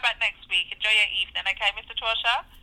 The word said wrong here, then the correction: Torchia: It's actually pronounced Torchia. – Are you just... You're just, like, playing the field with me, ain't you? – No. I wouldn't do that to you. – You told me before that it was Torsha. – No, Torchia. It's three syllables.